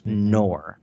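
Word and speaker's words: Knorr: It's called K-N-O-R-R. I believe it's pronounced Nor.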